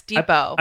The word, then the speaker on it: Depot: Oh, it's Depot. – I believe the T is silent.